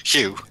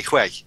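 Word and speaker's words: Huế: Actually, it's pronounced Hua. Well, like I say, this pedantic online criticism that I've found points out that even when she pronounces it Hua, it's wrong. It should be Hue.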